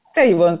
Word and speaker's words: Chabon: se escribe Michael Chabón, pero Chabon supuestamente.